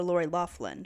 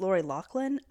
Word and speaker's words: Lori Loughlin: Okay, so some people call her Lori Loughlin. I call her Lori Loughlin because that's how it's spelled. But apparently it's Lori Loughlin.